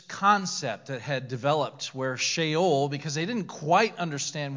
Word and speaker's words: Sheol: It's pronounced Sheol if you're ever reading through it.